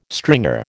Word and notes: stringer: American English

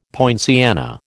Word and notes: poinciana: American English

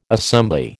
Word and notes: assembly: American English

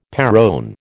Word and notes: perron: American English

